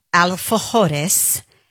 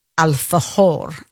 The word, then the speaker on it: alfajor: That's how much I fell in love with an alfajor. Is that the proper pronunciation, right? – Yeah, I believe so.